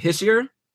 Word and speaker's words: Hischier: Is it Hischier? – Yeah. – There you go. I nailed it.